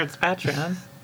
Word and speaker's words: Patreon: We have a Patreon now. – Is that how you say it? – Patreon. I'm pretty sure it's Patreon.